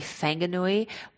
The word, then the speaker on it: Whanganui: And then sometimes in Maori, the WH you have to pronounce with an F if it's a word. So like Whanganui would be Fanganui.